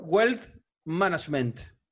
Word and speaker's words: wealth management: ¿Cómo, ¿Cómo ¿Cómo se dice wealth management? A ver el inglés de ustedes. A ver, a ver, decirlo nuevo. Wealth management. – Está bien, bastante bien.